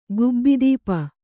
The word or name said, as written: ಗುಬ್ಬಿದೀಪ